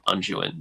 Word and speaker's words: unguent: I honestly just didn't have the time to look into the definition of unguent, but it intrigues me. – Yeah, someone look up UNGUENT, unguent, he says, is the pronunciation for that.